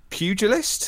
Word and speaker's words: Pugilist: The Pugilist? Is that how you pronounce it? – Yeah.